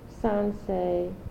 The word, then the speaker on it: Sansei: And this was given out to all Sansei, am I pronouncing it correctly? – Mm-hmm.